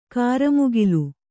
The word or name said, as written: ಕ್ರಿಮಿಕುಲ